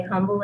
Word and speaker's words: Humble: Stay humble and humble. It is Humble, pronounced silent H.